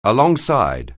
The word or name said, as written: alongside